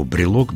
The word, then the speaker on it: брелок: Ну, потому что народ уж слишком настойчиво склонял слово брелок без этой гласной. О, брелка, брелком, да? – Так, давай по порядку. Брелок – слово французское? – Да. Французское. Ударение падает на последний слог, как всегда.